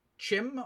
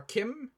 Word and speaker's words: CHIM: There is a concept called Chim or Kim. It's spelled C-H-I-M.